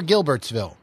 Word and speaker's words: Gilbertsville: Now, did you say Gilbertsville or Gilbertsville? – Gilbertsville. – It's a hard G, right? – Yes.